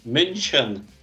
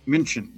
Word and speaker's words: München: München. – München, yeah.